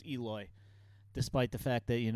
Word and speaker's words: Eloy: And a name slip up like Elroy, it has the name written, but then next to it on these note sheets, it's phonetically written. – Yeah, exactly. Yeah. – And he's one of the stars of the team. I feel like you should know Eloy Jimenez by now.